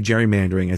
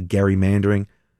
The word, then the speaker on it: gerrymandering: You know, I get mad sometimes when people say, or I just correct, not correct people, I inform people when they say gerrymandering. It's actually gerrymandering because Elbridge Gerry,